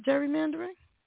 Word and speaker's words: gerrymandering: Didn't I just say that, gerrymandering? – Gary. – It's with a G. – It's pronounced gerrymandering. It's named after a guy whose name was Gary.